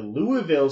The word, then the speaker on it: Louisville: All right, we have to talk about the Louisville Super Regional.